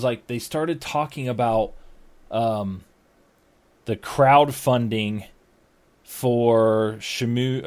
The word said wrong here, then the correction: Shenmue: or am I saying that right? Shamu 3? – Shenmue? – Yeah, one Shenmue.